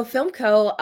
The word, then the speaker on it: Philmco: So FilmCo, so I'm the SVP of development and production at FilmCo, and it's spelled P-H-I-L-M-C-O, deliberately misspelled, sort of a combination of philanthropy and film.